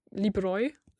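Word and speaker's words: libroj: So if we stay with this example libro, then the plural is libroi. – Libroi, yes. That's but the stress remains in the first syllable.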